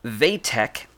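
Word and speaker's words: Wojtek: So despite the fact that it's spelled W-O-J, that is pronounced Vatek, was an orphan in 1942 when he found himself in the care of Inka Bukiewicz, in a Polish refugee camp near Turan.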